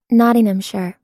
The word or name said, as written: nottinghamshire